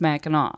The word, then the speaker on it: Mackinac: But that's another one that I was always like confused why one is C and one is W, and yet they're both pronounced Mackinac.